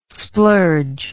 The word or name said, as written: splurge